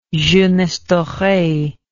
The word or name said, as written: jeunesse doree